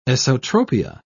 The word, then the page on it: esotropia: British English